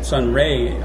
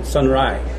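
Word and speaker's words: sonraí: I had said Sunray earlier, which is not correct. And Sunry is the way it's pronounced usually between us. But I looked on a site and of an Irish pronunciation site, and they said Sunry. – Yeah, is the actual Irish language accent.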